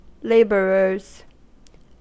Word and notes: labourers: American English

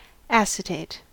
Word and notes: acetate: American English

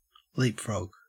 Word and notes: leapfrog: Australian English

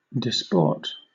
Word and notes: disport: British English

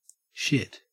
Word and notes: shit: Australian English

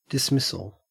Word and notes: dismissal: Australian English

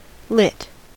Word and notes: lit: American English

